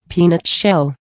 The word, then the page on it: peanut shell: American English